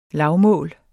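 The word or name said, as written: LAVMÅLS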